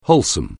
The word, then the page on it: wholesome: anglais